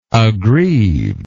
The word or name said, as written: aggrievedness